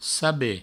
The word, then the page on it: saber: sabe